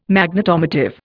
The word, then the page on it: Magnetomotive: Indian